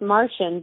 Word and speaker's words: Marcion: heard of Marcion of Sinope? – It's pronounced Martian, but no.